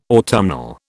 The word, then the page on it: autumnal: British English